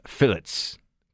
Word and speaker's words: fillet: And fried fish fillets, because in England, apparently they pronounce the T, fried fish fillet, fried fish fillets.